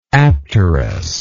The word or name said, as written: apterism